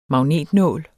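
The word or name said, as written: MAGNETNÅL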